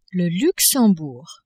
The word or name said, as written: Luxembourg